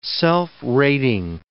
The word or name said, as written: self-rating